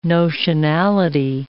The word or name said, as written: notionality